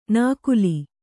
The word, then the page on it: ನಾಕುಲಿ: nākuli; Kannada